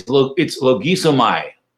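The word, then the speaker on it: logizomai: I realized this morning that I've been pronouncing it wrong all these years. I listened to it carefully. It's logisomai. Logisomai.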